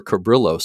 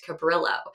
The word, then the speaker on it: Cabrillo: And in recent years, actually just I think 2 years ago, more evidence was found that Juan was actually born in Spain, which means that the pronunciation would be Cabrillo.